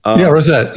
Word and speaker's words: Rosette: You call it the Rosette strategy. If I'm saying that right. – Rosette. Yeah.